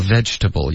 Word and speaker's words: vegetable: 然后 vegetable 也是一样， vegetable 就是美式发音英式发音都是三个音节的，现在词典里面有时候会标四个音节 vegetable，可是这个 veg 的这个的音就省了 vegetable